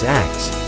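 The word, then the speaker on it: Akts: The musician's name is X, and I should spell that for you. That's A-K-T-S.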